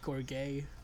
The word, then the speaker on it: gorget: How do you pronounce G-O-R-G-E-T? Is it gorget? It's French. It's French in etymology. – I guess it'd be gorget. – It's probably gorget. – Let me check. Gorget, says Google.